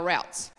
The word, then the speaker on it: route: routes. Now, I'm going to pronounce it route. I know some of us say route, like a pathway to somewhere. Okay?